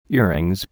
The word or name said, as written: earrings